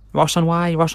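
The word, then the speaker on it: Ross-on-Wye: Tewkesbury and Ross. Ross on Wye.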